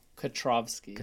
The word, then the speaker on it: Kutrovski: And can you pronounce your last name for me? – Kutrovski. – Kutrovski. – Yeah.